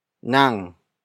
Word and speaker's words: nang: Nang is spelled as N-A-N-G.